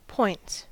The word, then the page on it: points: American English